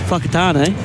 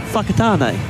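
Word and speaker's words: Whakatāne: Fakatane. – Yes. – Yes. – Sorry, where? – Fakatane.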